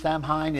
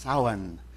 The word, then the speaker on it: Samhain: And now the last one is, it looks like it should be pronounced Samhain. It's not. It's pronounced Samhain.